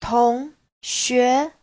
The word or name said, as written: tong2xue2